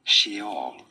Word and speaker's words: Sheol: There are two different ways of pronouncing it. – Two different ways. – This is normally said as Sheol. – Sheol. – Sheol. – See? – In English, however, it is generally said as Sheol. – Sheol. – Sheol. – Sheol. – Or in Hebrew again, Sheol.